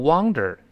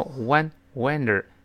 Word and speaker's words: wonder: No wonder，注意这个 wonder 不要读 wonder，那变成流浪了，四处流浪啊，要读成 one wonder。